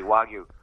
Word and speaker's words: Wagyu: Yes, it is Wagyu beef. Actually, good for you. You pronounced it correctly, Wagyu.